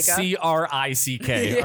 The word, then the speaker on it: creek: Yeah, how do you pronounce creek? – C R I C K. All right, it's goddamn crick.